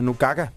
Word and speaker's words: Nukaka: Det viser sig, at vi har sagt det udtalt forkert. – Så hun hedder Nukaka. – Fordi G udtalt, eller K udtalt, ja. Som G på grønlandsk.